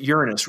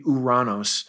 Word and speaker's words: Uranus: And I always tell people, I pronounce it Uranus. Really, it's Greek. It should be Uranos. But Uranus is sort of how we would pronounce it.